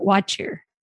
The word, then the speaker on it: What Cheer: And its town name is What Cheer. or as they pronounce it, Watcher, but it's spelled like What Cheer.